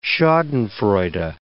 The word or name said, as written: schadenfreude